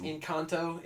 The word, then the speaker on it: Encanto: It's pronounced Encanto. It's Spanish.